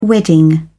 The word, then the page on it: wedding: British English